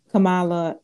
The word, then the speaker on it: Kamala: I feel like people are like, I haven't heard anything that Joe Biden or Kamala Harris.